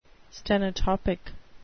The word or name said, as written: stenotopic